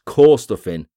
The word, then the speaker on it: Corstorphine: That's Corstorphine. Corstorphine, for all of the pronunciation police out there, thank you.